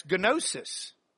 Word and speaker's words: gnosis: Now, there's another Greek word that's gnosis. Gnosis. And good, that's a long O.